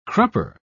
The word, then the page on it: crupper: British English